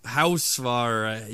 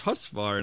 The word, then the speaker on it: Husvar: I just remember I was so happy they pronounced my name right, because, you know, I'd get Husfar, Housefar, Husfar. And, you know, he said, it's Husvar.